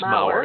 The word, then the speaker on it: Maurer: Well, listeners, if you want more information about Rick Maurer and Maurer, I'm sorry, I think I spoke your last name wrong. – Yeah, it's Maurer. It's a really hard one.